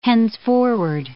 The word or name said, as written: henceforward